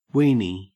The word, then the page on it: weenie: Australian English